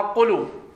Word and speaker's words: قلو: وبتغير ما أوله مفتوح كسنة أصلها سنو صارت سنين بكسر السين، وبوجهين ما أوله مضموم كقله أصلها قلو قلو، شلون أصلها بضم القاف وفتح اللام وسكون الواو،